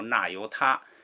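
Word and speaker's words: 那由他: 这个上一个经我们讲了两个印度的数位，一个是叫这个巨支，是 1000万1000万，还讲了一个叫挪油托，我们就随现在的人的这个读音就纳由他，这个是 1000亿，记住这两个是很常用的。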